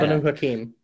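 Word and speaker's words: Banu Haqim: Like they just came out, like, cause I just did finished one, for the Banu Hakim. Is that how you pronounce that? – Banu Hakim.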